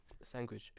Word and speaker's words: sandwich: Sandwich. – This word has no G in it. Not one. But he had a sandwich.